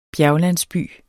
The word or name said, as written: bjerglandsby